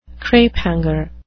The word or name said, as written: crapehanger